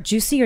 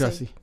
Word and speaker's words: Jussie: Juicy or Jussie? – It's just Jussie. Jussie mm-hmm.